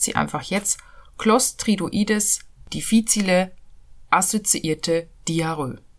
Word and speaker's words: difficile: Clostridium difficile oder Clostridium difficile, wie es eigentlich ausgesprochen wird, ist veraltet.